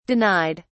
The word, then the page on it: denied: British English